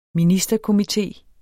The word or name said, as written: ministerkomité